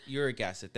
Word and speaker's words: uric acid: Uric acid? – Uric acid.